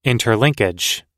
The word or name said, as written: interlinkage